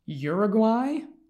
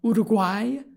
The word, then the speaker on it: Uruguay: I mean, I think maybe with the exception of uh, of, of Switzerland and Uruguay or Uruguay to uh, pronounce it a bit more like an Uruguayan.